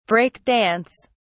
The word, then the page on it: break dance: British English